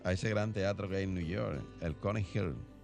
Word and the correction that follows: Carnegie Hall: ¿verdad que sí? Así se pronuncia. El, eh, Carnegie Hall.